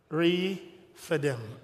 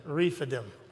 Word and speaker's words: Rephidim: Rephidim. Rephidim. Rephidim.